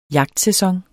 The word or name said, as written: jagtsæson